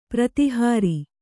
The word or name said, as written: ಪ್ರತಿಹಾರ